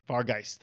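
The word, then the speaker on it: Vargheist: I'm just going to call it Giant Vargeist. – Batman. – Vargeist. – Is that how you say that? – Vargeist. – Vargeist. There we go. So we got three Vargeist in that too.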